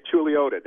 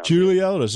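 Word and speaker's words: Chuluota: Well, we say Chiluta down Chiluta. Sorry about that.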